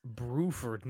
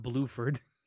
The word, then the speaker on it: Bruford: It's Bruford, not Bluford. – I thought it was Bruford. – No, B-R-U-F-O-R-D. Bruford.